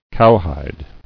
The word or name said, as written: Cowhiding